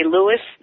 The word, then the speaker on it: Luis: Now, his name is spelled L-U-I-S. – Yes. – Russell. – Yes. – And he was born in Panama. – He was born in Panama. You can say Louis. They say Luis down there.